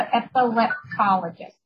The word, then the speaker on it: epileptologist: So epileptologist. Did I say it right? – Yes, right.